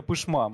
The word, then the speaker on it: Пышма: Или да, Верхняя Пышма. Москвичи часто говорят Пышма, Пышма да, но это неправильно.